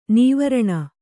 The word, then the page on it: ನಿವಾರಣ: nivāraṇa